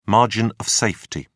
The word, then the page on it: margin of safety: British English